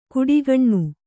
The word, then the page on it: ಕುಡಿಗಣ್ಣು: kuḍigaṇṇu